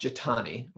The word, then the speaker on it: JETAANY: So anyway, okay, so then you come back to New York, you joined JETA New York, or as it's often called, JETAANY, rhymes with Armani.